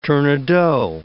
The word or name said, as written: tournedos